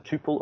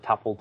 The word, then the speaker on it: tuple: Is it tuple or is it tuple? Take your pick. And I actually find, and I've been picked up on this, I use both. It just depends on what I'm saying at the time. – Because you're fickle. – I'm fickle. I'm a precisionist. And I accept that there are two different pronunciations.